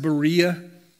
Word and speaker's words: Berea: In our Bibles, it's spelled EA, so it's commonly pronounced Berea.